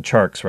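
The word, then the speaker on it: Tjarks: So let me just finish off the story that Jonathan Chart wrote for The Ringer. Jonathan Charks, rather, excuse me.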